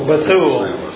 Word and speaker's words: Bethuel: Bethuel. – A lot of people pronounce that different, but that sounds good to me. – Accent on the second syllable.